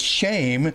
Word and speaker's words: Shem: Actually, the Hebrew has a long E, which is pronounced as an A. So it's shame, but not shame like, oh, that's such a shame. It's the word Shem, but it's pronounced in Hebrew, shame.